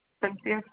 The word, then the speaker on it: Cynthia: Cynthia, C-Y-N-T-H-I-A.